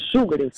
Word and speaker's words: шугаринг: Поэтому ударение в нем нужно ставить на первый слог и говорить правильно еще раз. Как? – Шугаринг.